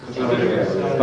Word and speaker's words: Caesarea: How do you pronounce it? – Caesarea. – Huh? Caesarea, right? From